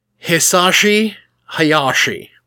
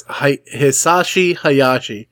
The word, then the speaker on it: Hisashi Hayashi: Hisashi Hayashi. – Yeah, that's right. – I'm about the whitest dude that's ever said those that name in existence. Hisashi Hayashi.